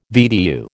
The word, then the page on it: VDU: American English